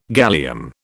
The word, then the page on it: gallium: British English